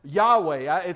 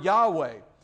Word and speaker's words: Yahweh: The word Lord is actually from a Hebrew word that we pronounce Yahweh. I don't believe it's really supposed to be pronounced, because it's the first initials of each word. When God said, "I am that I am," the first initials of each word in Hebrew, but we'll pronounce it this way for us today because it's easier to talk about. We pronounce that word Yahweh,